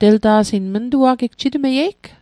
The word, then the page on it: mntua'gi: Mi'gmaq